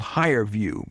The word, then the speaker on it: HireVue: And then I was reading Forbes, and they touted something called HireView, but it's spelled funny, H-I-R-E-V-U-E.